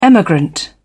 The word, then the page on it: emigrant: British English